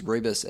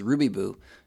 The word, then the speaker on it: rooibos: It's called rooibos chai, and my grandma always would pronounce rooibos as ruby boo, so I think of her every time I drink it.